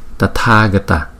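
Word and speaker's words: Tathāgata: Chữ A có dấu gạch ngang trên đầu, chúng ta phải đọc kéo dài 2 nhịp so với chữ A bình thường không có dấu gạch ngang trên đầu. Cho nên chữ này chúng ta đọc là Tathagata, Tathagata, Ganganam, Ganganam.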